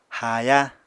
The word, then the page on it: haaya: Apatani